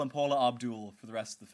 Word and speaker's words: Abdul: And when I asked him how to pronounce it, he said Abdul, like Paula.